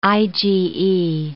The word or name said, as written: IgE